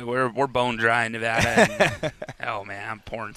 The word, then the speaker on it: Nevada: Not Nevada, it's Nevada.